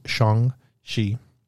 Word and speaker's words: Shang-Chi: So obviously we know that Black Widow comes out July 9th this year, followed by Shang-Chi, and that's the way to say it. And when he gets back, I'll ask him how he says it, but it's actually pronounced Shang-Chi.